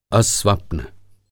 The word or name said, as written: अस्वप्न